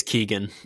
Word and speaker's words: Keoghan: I think it's Keegan. I've heard it pronounced that way on other podcasts.